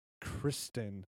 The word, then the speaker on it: Christine: Like, it's pronounced Kristen. – But I'm going to call him Christine because that's how he spells it. – Okay. – Christine Michael.